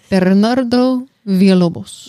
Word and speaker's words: Bernardo Villalobos: I can't roll my Berna, I can't roll my R's. Bernardo. – Ber. Ber. You know what? Perro. – You know what? Mr. V. Bernardo Villalobos. Yes. So you can roll your R's.